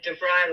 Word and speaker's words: DeVyre: Oh, it's like DeVyre, like rye bread or rye whiskey, whichever you DeVyre.